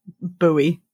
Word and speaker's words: buoy: I'm always still mildly confused by the American pronunciation, buoy.